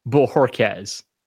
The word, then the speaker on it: Bohorquez: You love Corey, but you don't know how to say his name. – I'm telling you, I'm saying it right. – And it's fitting that your mic cut out while you said that. It's Corey Bohorquez.